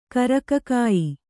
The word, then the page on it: ಕರಕಕಾಯಿ: kannada